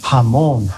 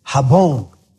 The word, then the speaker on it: jamón: And before she went, she said, now, how do you say ham? It's jamón. Okay. She went in and she says, I want, quiero medio kilo de jabón.